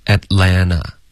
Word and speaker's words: Atlanta: 那你就说 Atlanta 就不读 Atlanta 了，那两个 T 都会省略。在美式的发音中两个 T 都肯定省略。 – 那会读成什么呢？ – Atlanta。